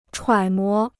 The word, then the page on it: 揣摩: chuǎi mó